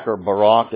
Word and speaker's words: Barak: Deborah, after the victory that Barak, is how you pronounce it, this is affected by God, actually, through Jael.